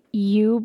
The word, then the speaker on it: yew: Someone says, can an overgrown yew bush be trimmed now or just wait until spring? It is large and beautiful, like Y-E-W. Did I say that right? Yeah, right. That's a U.